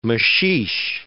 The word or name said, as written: maxixe